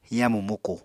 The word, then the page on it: yamu muku: Apatani